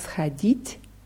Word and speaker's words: сходить: Ich, Raditili, Хатят Schodit, Na, Vistavku. – Ja, also du hast das Verb richtig gewählt hier, Schadit, nur die Betonung ist auf i. Их родители хотят сходить на выставку.